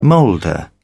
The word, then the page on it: molder: British English